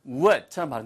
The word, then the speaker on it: would: 발음은 would라고 들리지 않고 입술이 내민 상태에서 들으면서 would처럼 발음됩니다.